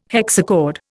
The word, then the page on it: hexachord: Indian